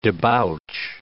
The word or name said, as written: debouch